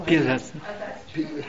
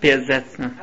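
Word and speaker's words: Piaseczner: And they blossomed, he became the Besetzner Rebbe. And am I pronouncing it right? – Piazetzner. – How do you pronounce it? – Piazetzner. – Piazetzner, Rebbe.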